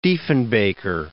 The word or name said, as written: Diefenbaker